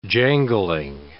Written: jangling

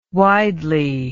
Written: widely

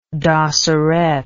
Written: dosseret

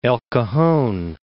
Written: El Cajon

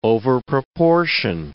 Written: overproportion

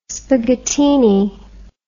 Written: spaghettini

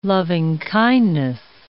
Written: loving-kindness

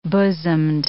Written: bosomed

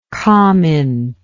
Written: comint

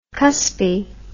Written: cuspy

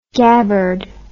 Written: gabbard

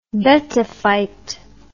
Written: betafite

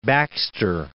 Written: Baxter

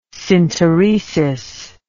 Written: synteresis

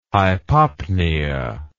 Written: hypopnea

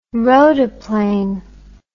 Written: rotaplane

